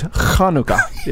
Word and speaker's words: Hanukkah: The pronunciation is Hanukkah. Yes.